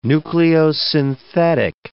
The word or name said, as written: nucleosynthetic